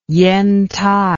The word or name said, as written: Yentai